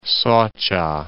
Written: Soca

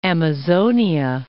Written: Amazonia

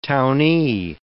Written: townee

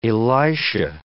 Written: Elisha

